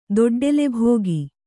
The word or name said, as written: ದೊಡ್ಡೆಲೆಭೋಗಿ